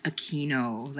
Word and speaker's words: Aquino: At the same time, I want to acknowledge that the pronunciation is Aquino, like oh Aquino. Okay.